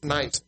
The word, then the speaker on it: Nice: The venerable Paphnutius in the Council of Nice," might be pronounced, no, it's not Nicaea, it's Nice, N-I-C-E, "quoted this verse for the justification of the married state."